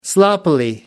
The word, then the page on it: sloppily: British English